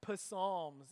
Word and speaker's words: psalms: Did you know that in Greek, which is where the word psalm comes from, the P was actually pronounced like in other words in our language that have a silent P on the front of them had a pronounced P, pneumonia, pterodactyl, psalms, and now I'm introducing you to psummer.